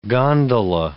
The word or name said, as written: gondola